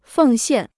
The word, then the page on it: 奉献: fèng xiàn